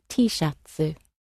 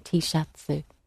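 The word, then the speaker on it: T-shatsu (Tシャツ): And other words that you'd probably recognise, T-shatsu. – T-shirt. – T-shirt. So again, the T sound at the end gets lengthened out into a softer vowel sound, T-shatsu.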